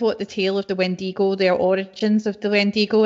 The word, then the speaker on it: Wendigo: Is that how you say it? Wendigo. – Oh, yeah.